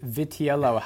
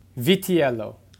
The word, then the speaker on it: Vitiello: Vitiello. Yeah, you did. Some people will say Vitello, but that's not correct. It's Viti. – Vitiello.